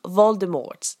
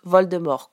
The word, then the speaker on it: Voldemort: J.K. Rowling a d'ailleurs publié sur Pottermore, site officiel de Harry Potter, que le nom doit en réalité être prononcé à la française. Donc les Anglais le prononcent Voldemort, mais en réalité ça se prononce Voldemort, comme nous le prononçons.